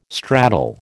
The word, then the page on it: straddle: American English